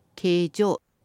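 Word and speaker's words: 京城: この形状っていうのは東京の京にお城と書いて形状と読むんですが、まあ、都のことです。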